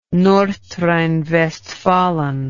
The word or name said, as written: Nordrhein-Westfalen